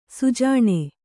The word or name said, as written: ಸುಜಾಣ